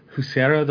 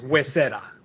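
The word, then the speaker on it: Huesera: It's Wesera. We, not you.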